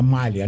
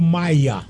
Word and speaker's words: malha: Que joga malha, né? – Sim. – É, a gente fala malha ou maia? – As duas pronúncias são corretas. Eu utilizo mais malha.